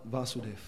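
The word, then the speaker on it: Vasudev (वासुदेव): The son of Vasudev. So I probably mispronounced it. Vasudev with the prolonged A.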